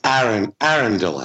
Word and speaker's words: Arundel: It's Arundel House. Not Arundel.